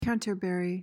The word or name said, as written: canterbury